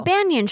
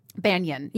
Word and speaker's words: banyan: Oh, a banyan tree. – Banyan. – Yeah. – Is that how you pronounce it? Banyan? – B-A-Y-A-N. – B-A-N-Y-A-N? – Yeah, yeah, yeah. – Yeah, yeah. Banyan.